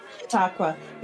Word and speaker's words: Chautauqua: how do you say it? Chautauqua? Is that how you say it? – Chautauqua.